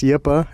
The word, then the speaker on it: Yppah: It's Yip-Ah, and it's spelled Y-P-P-A-H, and it's just happy backwards. I didn't think it was going to be too confusing, but nobody ever really seems to get it. So it's just Yip-Ah now.